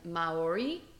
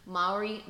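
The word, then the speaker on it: Māori: I had to actually look it up, and Maori, I believe is how you say it, Maori, so I apologize if I'm, like, fucking that shit up.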